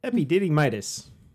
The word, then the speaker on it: epididymitis: So, shall we talk about my horrific bout with epididymitis, if I'm pronouncing that correctly, after so many whiskeys? – Close enough.